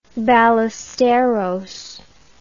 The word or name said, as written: Ballesteros